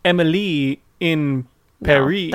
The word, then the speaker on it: Emily in Paris: but may I remind you, it's supposed to be pronounced Emily in Paris. – No, that can't. – So that it rhymes.